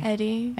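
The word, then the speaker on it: Hedi: Eddie or Eddie? – Eddie. – Eddie. Just wondering, just making sure. – Yeah, yeah. French pronunciation.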